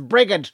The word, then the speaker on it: Brigid: That's not how you pronounce it. It's not Bridget, it's Brigid.